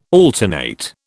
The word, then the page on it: alternate: British English